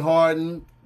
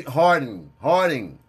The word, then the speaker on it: Harding: You ain't got to say my name like it's French now just because I told you. – Y'all make sure y'all go check him out, man. On Instagram, Gene Harden. Harding.